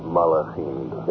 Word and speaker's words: Malachim: Malachim.